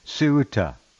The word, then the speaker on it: Ceuta: C-E-U-T-A, Ceuta, correct? – Yeah, Ceuta.